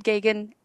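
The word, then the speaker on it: Geoghegan: He says, "Think of a gay gun, or it rhymes with Reagan." And so, yeah, Ted Gagan and Barbara Crampton from We Are Still Here.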